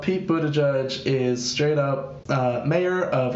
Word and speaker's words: Buttigieg: No, it's Buttigieg.